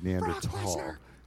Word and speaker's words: Neanderthal: That's actually the correct pronunciation of the word, Neanderthal. Right? Oh. It makes you sound pretentious when you say it, though. You just say Neanderthal like everybody else.